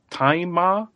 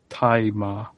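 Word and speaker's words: Tzi Ma: Who is played by, I think his name is pronounced Taima. It's spelled T-Z-I, and then his surname is just Emmy, but so I think it's Taima. It's how you pronounce that.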